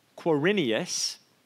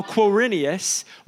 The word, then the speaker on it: Quirinius: This was the first census that took place while Quirinius, every year, I'm like, say this right, and I nailed it yet again. So while Quirinius was governor of Syria, and everyone went to their own town to register.